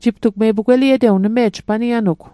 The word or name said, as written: pugweliet